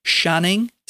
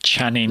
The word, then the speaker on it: Channing: And is it Channing Tatum, or are you supposed to pronounce it Shanning, like S-H? – Channing. – Like a chandelier is technically C-H? – I'd say Channing. – Like Jackie Chan.